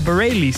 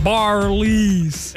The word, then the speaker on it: Bareilles: Oh, spreek je het zo uit? – Ja, Bareilles. – Ja, ik kwam er net helemaal niet uit, maar goed. – Of is het gewoon Bareilles? – Ik dacht Bareilles. – Bareilles, dat had ook gekund.